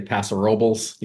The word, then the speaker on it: Paso Robles: It's also, I grew up in a Latino community, and we'd be talking Spanglish, and but even they would say Paso Robles. You know, it's very precise pronunciation.